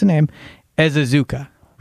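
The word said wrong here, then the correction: Ezeiruaku: Ezaraku. Ezaraku. – Ezaraku. – That's how you pronounce it.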